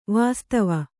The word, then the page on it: ವಾಸ್ತವ: Kannada